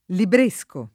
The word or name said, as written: libresco